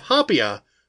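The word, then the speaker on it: haupia: In between is a layer of hapia, which I don't have any idea if I'm pronouncing that correctly, H-A-U-P-I-A, a gelatinous coconut pudding made with coconut milk, cornstarch, and sugar.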